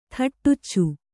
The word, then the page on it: ಥಟ್ಟುಚ್ಚು: thaṭṭuccu